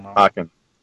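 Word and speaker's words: Pokkén: Oh, yeah. Pokken. Pokemon X. – And Tekken combined. – Yeah. Pokken. That's why I always say it like that.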